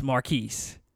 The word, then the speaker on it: Marquise: He says, how do you say Marcus's name? And I was like, it's Marquise. He's like, oh, so is French.